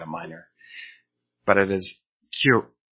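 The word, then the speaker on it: kyu: It is not, as I have heard sometimes, some people might say Kui or Kaiyu. Kaiyu is a character, cartoon character on television in Canada. But it is neither Kaiyu nor is it Kui. It is Q-K-Y-U, using the Japanese pronunciation rules.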